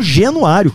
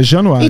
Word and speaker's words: Januário: As pessoas falam São Genuário, cara. – É Januário.